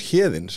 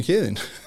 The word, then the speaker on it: Heðinn: But it is funny because my name is Heðinn, so that's it rhymes in a sense. So Gethin and Heðinn.